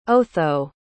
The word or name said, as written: otho